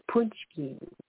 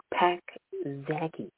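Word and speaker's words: pączki: I has punschki. Punschki is that delicious dessert that looks like it's supposed to be pronounced Pak Zaki.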